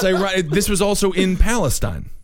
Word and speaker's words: Palestine: In fact, in Texas, it's pronounced Palestine. – Well, of course, because Palestine is how they would say it in the Middle East. And we're different here in Texas.